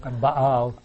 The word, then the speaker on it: Baal: Maybe we'll go with, pronounce it for me, B-A-A-L. – Baal. – Baal. – Baal. – I say Baal.